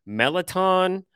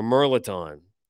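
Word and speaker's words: mirliton: Merloton is what it looks like, but I've seen it pronounced mostly melaton or merloton.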